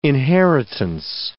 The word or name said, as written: inheritance